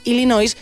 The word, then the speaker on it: Illinois: En inglés, el nombre del estado de Illinois se escribe exactamente igual que en español, pero se pronuncia de manera muy distinta debido a su origen francés. Escritura Illinois, pronunciación Illinois.